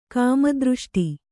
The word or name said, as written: ಕಾಮದೃಷ್ಟಿ